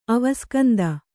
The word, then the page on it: ಅವಸ್ಕಂದ: avaskanda